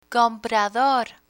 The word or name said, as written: comprador